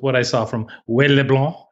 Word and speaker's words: LeBlanc: If you're going to say his name, you've got to say it correctly. It's Wade LeBlanc. – I'm sorry. Could I have that one more time? – It's Wade LeBlanc.